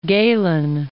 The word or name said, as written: Galen